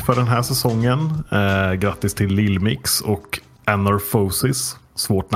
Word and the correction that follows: Anorthosis: Anorthosis eh, famogusta.